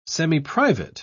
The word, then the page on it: semiprivate: British English